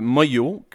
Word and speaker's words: Manglo: Avant ça, on a entendu « Écoutez tous mon histoire » interprétée par Moyo, mais ça se prononce ça s'écrit Manglo, M-A-N-G-L-O, ça se prononce Moyo